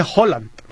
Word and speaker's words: Haaland: Sí, se supone que se pronuncia Haaland.